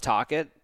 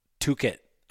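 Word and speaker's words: Tocchet: How do they pronounce Tocket again? Rick Tocket? – Tuchet, I think. – Yeah. Interesting, Rick Tuchet.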